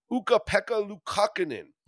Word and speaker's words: Ukko-Pekka Luukkonen: Ukko-Pekka Luukkonen scheduled to go in net for Buffalo. Yeah, you heard me correctly. – Yeah. – I said that right.